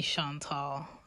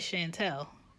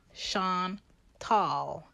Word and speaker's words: Chantal: My name is French. It is spelt C-H-A-N-T-A-L. Yes, I may be short, but at the end is the tall, okay?